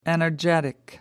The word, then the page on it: energetic: American English